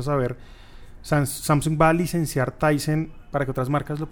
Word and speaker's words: Tizen: El sistema operativo que trae este televisor sigue siendo, ¿cómo se dice? Tizen o Tyson? Tizen Sigue siendo Tizen Sí.